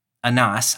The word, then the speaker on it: Annas: Annas. I say Annas because I'm from Arkansas, but your pronunciation is better to the original.